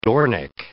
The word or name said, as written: dornick